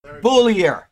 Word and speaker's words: Boulier: And if he spelled me his name, and I said, oh, that's French. And as soon as I said that, I keep wanting to say something like Boulier. And so I can't remember his name now because of that. I should have just went with the American pronunciation, and it's wonderful to have you here, sir.